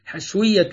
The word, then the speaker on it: الحشوية: الحشوية تنطق بتسكين الشين وتحريكها فتحا، حشوية وحشوية،